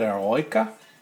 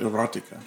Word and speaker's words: Eroica: Speaking of winding things up, isn't that Eroica? – I'm calling it Erotica. – There's no T. That's an I.